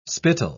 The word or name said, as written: spittle